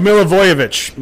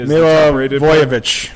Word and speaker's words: Milivojevic: Mihalovic with 10. – Oh, Milivojevic, excuse me.